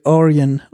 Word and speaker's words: Orion: On the back, you've got the Orion logo.